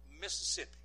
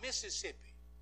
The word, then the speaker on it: Mississippi: You pronounce that state like a single woman named Sippy. It's Mississippi. It's not Mississippi. I just thought I'd educate you on that. It's Mississippi.